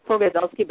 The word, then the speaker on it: Pogorzelski: And you know, you don't have to try to pronounce my name. It's Pogazelski, but everyone calls me Jodi Pogo.